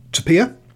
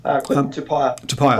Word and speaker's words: Tupaia: But a player that you will be hearing a lot of, Quinton Tupaia. Have I got that right? – Tupaia, close. Sorry. Close. the reason I can't get his name is pronunciation right is because in the press box everyone just calls him Quinn.